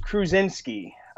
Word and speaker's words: Kruzinski: I'm actually out of Rochester, New York, outside, just east of Buffalo, between Buffalo and Syracuse, and last name is pronounced Kruzinski. Ten-letter Polish last name.